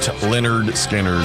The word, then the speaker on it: Lynyrd Skynyrd: Pronounced Lynyrd Skynyrd.